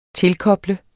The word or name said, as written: tilkoble